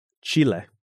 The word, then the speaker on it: Chile: Yeah, it's spelled like the country, right? – H-I-C-K-C-H-I-L-E. – Chile. – Chile.